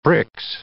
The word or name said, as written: Brix